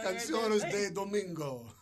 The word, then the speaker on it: Canciones de Domingo: Canciones de Domingo. – Exactly. Yeah, no, but you said it right.